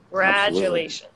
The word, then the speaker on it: congratulations: The stress is on the second syllable. Congratulations. – Absolutely.